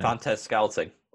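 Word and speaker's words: Valdes-Scantling: Vantes Schulting. How do you say his name? – Vantes Scouting.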